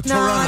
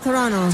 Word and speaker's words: Toronto: Hagamos un programa especial desde, no se dice Toronto, se dice Toronto. Repitan conmigo, Toronto.